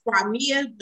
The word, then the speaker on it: Qimmah: And listen, it is actually pronounced Crimea, but normally if people cannot pronounce it, I just tell them to call me Q, Queen, or Qua.